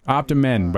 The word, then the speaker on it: Optimen: Optimen, but it's spelled men, like it's a man multivitamin.